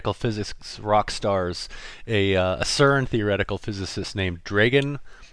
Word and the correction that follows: Dragan: It looks like it's spelled Dragon, but it's Dragan Hajduković.